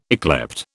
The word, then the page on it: yclept: British English